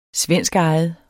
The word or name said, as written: svenskejet